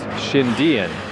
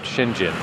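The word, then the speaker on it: Xindian: But here it's capital X-I-N, capital D-I-A-N. So that like tips you off that you say it like it's two words. Shindian, not Shinjin.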